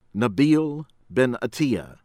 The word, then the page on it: Nabil Benattia: American English